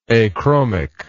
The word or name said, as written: achromic